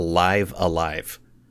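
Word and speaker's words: Live A Live: And it, apparently, according to the Nintendo Direct trailers, it is, you pronounce it Live Alive.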